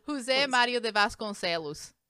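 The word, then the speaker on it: José Mauro de Vasconcelos: Jose Mario de Vasconcelos.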